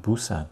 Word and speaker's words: Busan: Oh, that's the way we pronounce it, Busan.